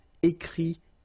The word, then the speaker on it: écrit: Okay, remember, you put this T, you don't pronounce it. Écrit.